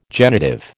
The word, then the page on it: genitive: American English